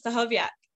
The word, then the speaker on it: Stachowiak: Very close. Stachowiak. – Stachowiak, sorry. I forgot there was no K.